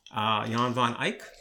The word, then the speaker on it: Jan van Eyck: and that would be Jan van Eyck.